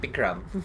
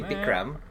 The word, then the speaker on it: Bikram: So if you're going to say Bikram, say it like he would.